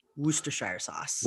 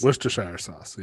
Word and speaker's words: Worcestershire: And that minus the Shire, the way uh, from what, everything I read, the way you're supposed to pronounce that is Worcester. Worcestershire sauce. – Worcestershire sauce.